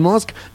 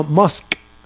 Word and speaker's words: Musk: ¿Cómo se dice, tarugo? Dígalo otra vez. – Musk. – No, menso. Así no. – Haga de cuenta que va a decir mosca y le ajá. quita la A.